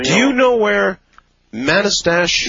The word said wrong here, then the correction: Manastash: Yeah, it's pronounced Manastash.